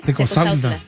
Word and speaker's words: Tecozautla: Rubén Martínez, buenas tardes y un saludo para toda la raza de, vamos a ver si lo pronunciamos bien, Tecozautla y Portugués Querétaro, de parte de Ervin y Rubén, que andan trabajando en Jefferson con una canción. Tecozautla, creo que sí se dice. – Tecozautla.